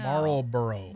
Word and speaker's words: Marlboro: Marlboro.